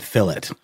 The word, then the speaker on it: fillet: Because that's how the British pronounce fillet. They pronounce it fillet. – Really? – They do a hard T sound at the end of it.